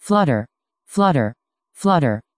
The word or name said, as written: flutter